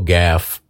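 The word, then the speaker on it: Gauff: I think her last name is pronounced Gaff. Coco Gaff play tennis in the semifinals of the U.S.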